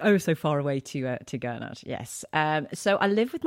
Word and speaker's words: Gurnard: I thought it was pronounced Gurnard, but I'm learning how to say it like a local.